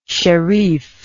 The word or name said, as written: sherif